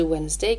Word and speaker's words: Wednesday: Attention à l'orthographe de Wednesday qui ne s'écrit pas du tout comme il se prononce.